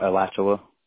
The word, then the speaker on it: Alachua: Yeah, Alachua.